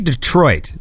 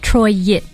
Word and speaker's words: Detroit: It's almost like there's two syllables on the end. Troy-it. There's like a diphthong there.